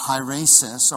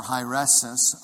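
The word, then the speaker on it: hieresis (αἵρεσις): And the Greek word is pronounced hieresis or hieresis, okay?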